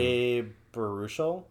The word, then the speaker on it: Baruchel: Oh, Jay Baruchel. How do you say his last name? – Jay Baruchel? – Yep, that sounds right.